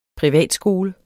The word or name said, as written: privatskole